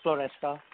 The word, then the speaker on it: Floresca: Yes, you pronounced my name right. It's Ulrich Floresca.